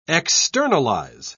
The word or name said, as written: externalize